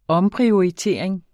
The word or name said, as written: omprioritering